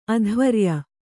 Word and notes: ಅಧ್ವರ್ಯು: adhvaryu

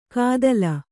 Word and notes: ಕಾದಲ: kādala

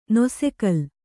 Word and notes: ನೊಸೆಕಲ್: nose kal

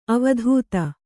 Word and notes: ಅವಧೂತ: avadhūta

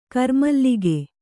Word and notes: ಗಿರಿಮಲ್ಲಿಕೆ: Kannada

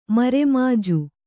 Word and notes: ಮರೆಮಾಜು: mare māju

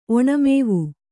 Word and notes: ಒಣಮೇವು: Kannada

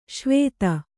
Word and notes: ಸವಿಧೆ: Kannada

